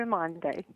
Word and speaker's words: Umande: Am I pronouncing that correctly? – Yes, A Mom for Umande.